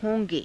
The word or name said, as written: ho~ge